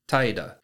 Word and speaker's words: taida: Today's Lessons is going to be the Japanese word for sloth, and that is taida. Taida is spelled T-A-I-D-A.